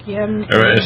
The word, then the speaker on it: Guillain-Barré: Guillain-Barré. – Ascending paralysis. – So similar? – Yeah, I saw cases over the years that would actually get it, and they would have Guillain-Barré, and hopefully it would stop before it got, you know, above their legs, and then with time it would gradually get better.